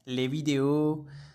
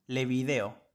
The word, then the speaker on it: vidéo: No es lo mismo decir el video que decir le vidéo. Y si yo intentara decir el video con mi entonación o mi pronunciación argentina en francés, yo diría le video.